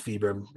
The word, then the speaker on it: Vibram: So I started throwing, you know, Vibram with my buddy, and I think it's technically pronounced Vibram. It's Vibram. – You're right.